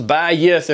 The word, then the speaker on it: bayith: It's two syllables, bayith, and it means my descendants forever.